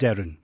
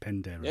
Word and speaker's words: Penderyn: It's Penderyn. Is that how you say it? Correct? – Penderyn. Yeah, Penderyn.